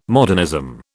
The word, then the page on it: modernism: British English